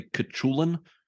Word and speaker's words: Cuchulain: and we get Cuchulain. – Yeah, which they also call the Irish Wolfhound, so they don't make us pronounce it. – I believe it's actually Cuchulain. – Oh, that sounds better.